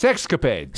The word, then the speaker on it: sexcapade: Uh, I don't know, they were involved in some sexapade, sex, sex, how do you say that? Sex, sexcapade. Sexcapade.